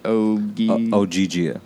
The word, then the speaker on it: Ogygia: Ogygia, yeah, which is an island in Homer's Odyssey.